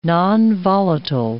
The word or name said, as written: nonvolatile